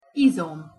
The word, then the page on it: izom: Hungarian